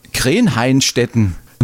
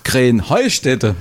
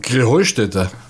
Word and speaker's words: Krähenheustätte: Ja, ihr saget immer Krähenhainstetten. Und dabei heißt es doch Krähenheustätte, oder wie ist es? – Krähenheustätte. – Ja, genau, und das die Betonung, ja, auf dem Heu.